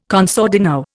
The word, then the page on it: con sordino: Indian